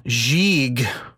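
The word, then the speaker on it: Gigue: So, Gieg, which I am pronouncing correctly, according to the lady in the Oxford Dictionary on my computer.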